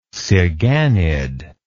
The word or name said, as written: siganid